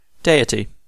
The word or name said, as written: tanrı